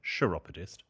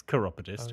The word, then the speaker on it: chiropodist: And yes, the chiropodist, a couple of people saying that they've never heard chiropodist pronounced like that. Some people have said chiropodist, the fools. It's chiropodist.